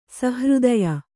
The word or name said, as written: ಸುಹೃದಯ